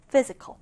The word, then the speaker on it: physical: 첫 음절의 강세를 두고 자, 이 단어 ph를 f로 발음해 줍니다. 그래서 physical.